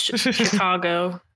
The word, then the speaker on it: Chigo: You said it wrong. It's Chicago.